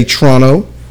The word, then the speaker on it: Toronto: Yeah, they promoted their match at NXT Toronto, or as locals say, Toronto.